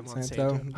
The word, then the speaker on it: Monsanto: Yeah, Monsanto.